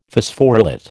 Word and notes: Phosphorylate: American English

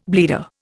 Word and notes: bleeder: Indian

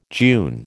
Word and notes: June: American English

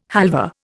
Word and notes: halva: Indian

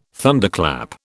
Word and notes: Thunderclap: British English

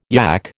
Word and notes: yack: American English

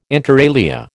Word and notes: inter alia: American English

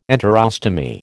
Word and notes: enterostomy: American English

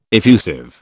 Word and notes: effusive: British English

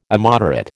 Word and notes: immoderate: American English

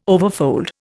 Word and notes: overfold: Indian

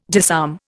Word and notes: disarm: Indian

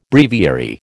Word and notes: breviary: American English

